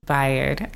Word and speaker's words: Bayard: Bayard.